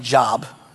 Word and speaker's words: Job: It looks like Job, J-O-B, just pronounced Job.